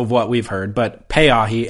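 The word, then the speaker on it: Pay-ahi (Peʻahi): This would be a good time to mention, I got an email from a listener correcting pronunciation on that surf spot, saying it's actually pronounced Pay-ahi. – Pay-ahi.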